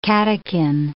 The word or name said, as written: catechin